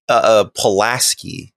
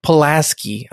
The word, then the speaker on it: Pulaski: And eventually we find out that Roman Polanski, Dr. Polanski. Is it Polanski? What is it? – Pulaski. – Pulaski. I've been saying it wrong this whole fucking time.